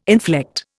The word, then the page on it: inflect: Indian